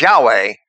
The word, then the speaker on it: Yahweh: So Moses is told by Yahweh to use his name, the Tetragrammaton, which we pronounce as Yahweh,